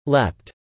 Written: Leapt

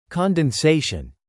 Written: condensation

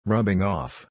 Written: rubbing off

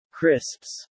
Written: crisps

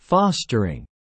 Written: fostering

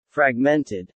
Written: fragmented

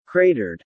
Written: cratered

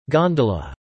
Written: gondola

